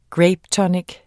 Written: grapetonic

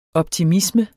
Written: optimisme

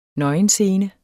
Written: nøgenscene

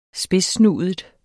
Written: spidssnudet

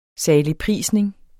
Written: saligprisning

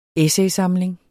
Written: essaysamling

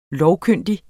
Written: lovkyndig

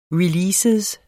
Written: release

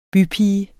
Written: BYPIGEN

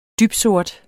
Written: dybsort

